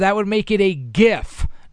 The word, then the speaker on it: GIF: Graphics is a hard G. It's GIF, just like the word gift, G-I-F-T.